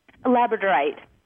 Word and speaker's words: Labradorite: Labradorite. – I don't know if I'm pronouncing it right. – What is it? What is it? – Labradorite.